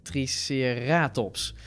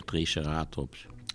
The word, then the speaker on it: Triceratops: Triceratops, of zoals jij altijd zegt, Triceratops. – Ja, ik zeg Triceratops. – Laten we dit consistent... Dat zei je vroeger, ja, dat is gewoon de klemtoon in het Nederlands.